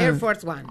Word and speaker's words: Air Force One: Al Air Force One, lo digo bien. – Air Force One, perfecto.